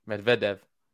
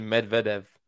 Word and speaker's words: Medvedev: Medvedev. – Yeah. – You actually said Daniil Medvedev. – Medvedev. – Medvedev. – I don't know. It's emphasis is always on the middle part.